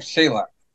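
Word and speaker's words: Salač: Salachi, maybe, in English. – I don't know. I thought they would call him Salach. – Salach is how it's pronounced. Philip Salach.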